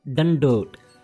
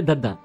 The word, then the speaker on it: dandot: The word is dandot. – Dandot? – You are saying a dandot. Dandot. It's a not a dada. It's a dada. Dandot.